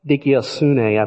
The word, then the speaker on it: dikaiosune: Righteousness, the Greek word for that is dikaiosune, I believe is how you pronounce it.